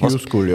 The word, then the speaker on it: Q-School: Anfang Januar 2019, wir machen einen kurzen Zeitsprung, ging es dann nach Hildesheim in die Q-School. Habe ich das richtig ausgesprochen? Q-School, ja.